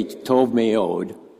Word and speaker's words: tov meod: So if you hear anybody say tov meod, that's what that means. And it's talking about how God viewed creation after the creation of humanity. Phonetically written, it's T-O-V-M-E-O-D, tov meod.